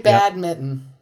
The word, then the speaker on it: badminton: No, Fletcher and I both have a slight N in there. Badminton. – Yeah. Just no, slight. I say badminton.